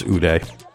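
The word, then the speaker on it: Oude: I think it's Ude. I think Ude is it's spelled O-U-D-E. I mean, Ude, that sounds better than Audi, right?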